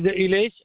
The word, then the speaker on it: Ilesh: Okay, first name is Ilesh and last name is Patel. – Okay, can you please spell it? – I-L-E-S-H.